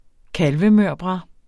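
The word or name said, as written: kalvemørbrad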